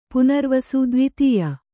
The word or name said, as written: ದೇವಾದಾಯ